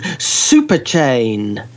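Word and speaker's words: Xuperchain: Now, it's launched its own cryptocurrency, which is called Superchain. Superchain, except it's spelt with an X at the beginning instead of an S to make it sound more crypto-ish, I guess.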